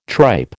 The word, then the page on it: tripe: American English